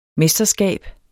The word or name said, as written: mesterskab